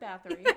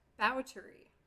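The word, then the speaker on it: Báthory: Okay, so Elizabeth Bathory. No. – Yes. So the more correct pronunciation is Elizabeth Bowtery.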